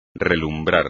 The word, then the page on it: relumbrar: espagnol